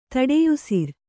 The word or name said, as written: ತಡೆಯುಸಿರ್